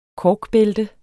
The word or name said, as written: korkbælte